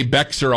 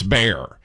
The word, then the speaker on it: Bexar: I say Bexar all the time and it's Bear.